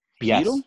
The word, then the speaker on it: Poeltl: Is that how you pronounce his name? – Poeltl? Yes. Poeltl.